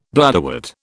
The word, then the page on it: bladderwort: British English